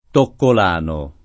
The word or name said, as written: toccolano